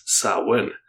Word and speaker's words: Samhain: It originated with the ancient Celtic festival of Samhain, which is spelt Samhain, but pronounced Samhain.